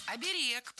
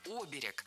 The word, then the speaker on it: оберег: Оберег, правильно. Но такая норма была зафиксирована недавно. До этого ударение следовало ставить на первый слог «оберег». А сейчас можно и так и эдак. И «оберег», и «оберег» теперь литературная норма.